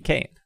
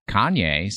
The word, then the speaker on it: Kayne: K-A-Y-N-E. Kane. – Yeah. – I think it's Kane. I don't want to... – It's not Kanye, so sure. – Kanye? How do you spell Kanye? – K-A-N-Y-E.